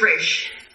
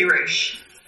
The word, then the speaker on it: Ra's: Yeah, it's Ra's. It should be Ra's. Because it is, in fact, Arabic. And that's the pronunciation.